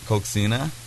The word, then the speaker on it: coxinha: All right, and then there was another thing on there that was COXINHA. How do you say that? – Coxina.